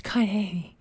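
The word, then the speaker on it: Kain (καινή): Literally the Greek word, I don't know, how do you pronounce that? – Cain. – Oh, yeah. Okay. It's not. Sometimes you make things harder than I need to. It's Kain. – K-A-I-N-E. The Greek.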